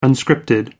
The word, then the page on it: unscripted: American English